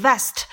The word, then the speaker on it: vest: 马甲是要发 v v，轻轻的咬住你的下唇。 Vest，这个呢是 w 要发 w 的音。